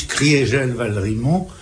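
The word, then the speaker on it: Kriegel-Valrimont: On prononce Criégel Valrimont, c'est bien ça, ou Criégel Valrimont? – On prononce Maurice Criégel Valrimont pour marquer que en 18, l'Alsace a cessé d'être germanisée.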